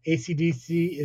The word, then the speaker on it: AC/DC: AC/DC is pronounced one letter at a time, though the band are colloquially known as Akadaka in Australia. Ah, Mudgee, you spelled it wrong. – How's it spelled? – It's A-C-C-A space D-A-C-C-A.